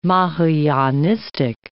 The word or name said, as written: Mahayanistic